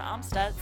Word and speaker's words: Amstutz: You can also find all my personal social media accounts at Sandra Amstutz.